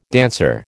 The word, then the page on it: dancer: American English